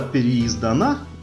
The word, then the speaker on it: переиздана: Эм, она тоже э, была переиздана, э, переиздана, как правильно говорить, переиздана или переиздана? Переиздана. Да, переиздана.